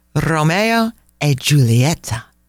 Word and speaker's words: Romeo e Giulietta: Romeo e Giulietta. That's the proper pronunciation.